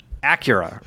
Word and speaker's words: Acura: Acura, right? – Yeah.